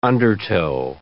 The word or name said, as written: undertow